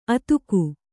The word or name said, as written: ಅದುಗು